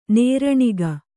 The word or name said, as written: ನೇರಣಿಗ